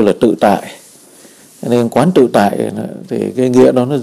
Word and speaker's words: Quán Tự Tại: Mình cũng có thể đọc là Quan Tự Tại, là Quán Tự Tại.